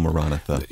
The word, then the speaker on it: Maranatha: Sing this song for us, which you pronounce Maranatha. Most of us call it Maranatha, but correctly it's called Maranatha. – Yeah, William Lane, the very fine teacher that we've had on this program a number of times, taught us to pronounce it that way.